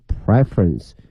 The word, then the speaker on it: preference: behavior 这个词 behavior behavior，以及呢它的一个偏好 preference preference preference，它由这个动词 prefer 喜欢呢变成了名词，在变化的过程当中它的发音发生了变化，从原来的 prefer 变成了 preference。